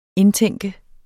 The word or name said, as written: indtænke